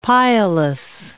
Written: pileless

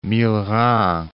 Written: Millerand

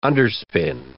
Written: underspin

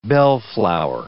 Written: bellflower

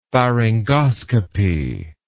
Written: pharyngoscopy

